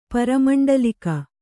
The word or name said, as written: ಪರಮಂಡಲಿಕ